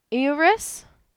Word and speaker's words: Eurus: Then there is, I'm trying to make sure I don't mispronounce it, Eurus. E-U-R-U-S.